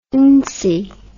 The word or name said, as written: ency